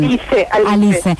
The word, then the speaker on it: Alice: Alice.